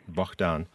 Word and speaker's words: Bohdan: Bohdan.